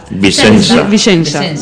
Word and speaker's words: Vicenza: Se pronuncia Vicenza, ¿verdad? – Vicenza.